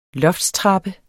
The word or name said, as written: loftstrappe